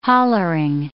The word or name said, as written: hollering